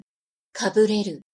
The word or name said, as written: kabureru